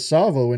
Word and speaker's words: Tsavo: Tsavo in Kenya. It's spelled T-S-A-V-O.